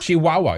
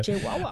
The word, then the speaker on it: Chihuahua: How do you pronounce it again? – Chihuahua. – Is that how you pronounce it? – Yeah. – Okay. I always forget. – Chihuahua.